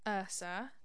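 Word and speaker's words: Yrsa: like, I don't want to do an injustice and say her name wrong, but I think it's pronounced Ursa, because it's Y-R-S-A, so I'm thinking it's Ursa Daily Ward.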